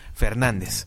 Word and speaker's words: FRDZ: Hoy nos toca hacer parada en tierras hidrocálidas para conocer a Fernández, escrito como FRDZ, pero que se pronuncia así meramente. Fernández.